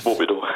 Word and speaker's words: Wobedo: So, W-O-B-E-D-O geschrieben. Was für ein Name? Wobbedo. Ich habe gedacht, spricht man es vielleicht Wobbedo aus oder Wobbedo? Sie sagen Wobbedo. – Genau, Wobbedo.